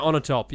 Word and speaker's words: Onatopp: How do you pronounce her name? Xena Onatopp, yeah.